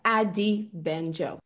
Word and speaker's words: Adi Benjo: Okay, and I'll get better at pronouncing your name because I think I've been saying Adi and you say it Adi. – Yeah, Adi Benjo.